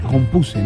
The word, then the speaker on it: compuse: No, no, no, se dice compuse, no compusí, compuse.